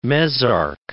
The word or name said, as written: mesarch